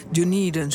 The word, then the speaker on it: Dunedin: Ja, maar goed, je hebt in ieder geval wel een paar gevonden daar in de Dunedin. Zo moet ik het dus uitspreken, die stad. – Ja, klopt inderdaad.